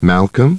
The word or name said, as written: Malcolm